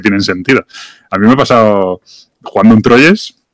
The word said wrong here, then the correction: Troyes: un Troa, que...